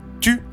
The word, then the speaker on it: Thou: Tu, orthographié T-H-O-U, mais bien prononcé tu, est ce genre d'énergumène.